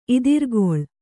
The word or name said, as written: ಇದಿರ್ಗೊಳ್